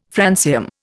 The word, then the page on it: francium: Indian